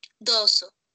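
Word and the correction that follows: dozo (どうぞ): Yoroshiku Ya, eh, todo súper bien eh, o trata de pronunciar un poquito más la Z, dozo, dozo, dozo, dozo, yoroshiku, yoroshi.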